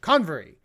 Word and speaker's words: Convery: This writer's name is Alex Coverny. Let me see if I can pronounce his last name. – Covery? – Convery. Alex Convery.